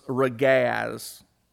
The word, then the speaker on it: ragaz: The word for quarrel is ragaz. R-A-G-A-Z.